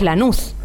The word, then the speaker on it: Lanús: La duda es cómo es que se acriolló la escritura, porque es Lanús. Lanús, sí, con sí, acento en la U.